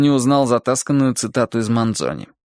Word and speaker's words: Манзони: А поэт Манзони, лучше даже Манзони, с ударением на последнем слоге,